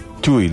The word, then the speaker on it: Chuy: Chuy, lo digo bien. Así es.